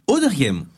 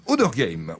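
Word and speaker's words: Auderghem: C'est Odergame ? – Odergame. – Odergame, oui.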